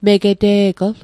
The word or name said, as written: megete'gl